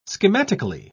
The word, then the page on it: schematically: British English